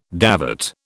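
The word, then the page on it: dévot: British English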